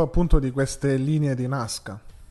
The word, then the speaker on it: Nazca: Io ho visto che in Perù usano con la C, con la S, scusa, non con la Z, però insomma si pronuncia Nazca, pronunciano come vuoi, è giusto.